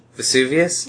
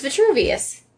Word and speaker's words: Vitruvius: Is that how you say it? Vitruvius? – No. – Vesuvius? – It's not Vesuvius. – I think it's Vesuvius. – It's Vitruvius.